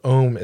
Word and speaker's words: Om: It's often chanted three times at the start and at the finish of a yoga session, and the sound of Om is actually three syllables, A, U, and M.